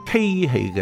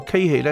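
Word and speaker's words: 欹: 在中国的荀子里面记载了一则故事，他说有一天孔子呢，他去参观鲁国的座庙，孔子留意到有一种叫做稽器的装水容器，这个稽字很难写，它的左边是奇怪的奇，右边是欠债的欠，读音就读个稽字，就是稽形的稽。这个稽器是一个装水的容器，但是只要将水倒进去的时候，如果一倒满，这个稽器就立即会翻侧倒泻了水。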